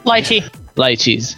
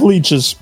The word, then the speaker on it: lychees: I can't pronounce that word. – Lychees. – It reminds me of exotic lychees. – Exotic leeches.